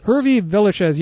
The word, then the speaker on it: Hervé Villechaize: Even though, and you have the silliness of I can't pronounce this actor's name, but the short actor from Hervé Villechaize. – Hervé Villechaize, yes, plays Knick-Knack in Man with the Golden Gun.